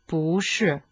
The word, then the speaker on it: 不: Di solito, pu viene pronunciato al quarto tonno. Quando però è seguito da un'altra sillaba al quarto tonno, modifica il suo suono in secondo. Bushi, ad esempio, si pronuncia bushi.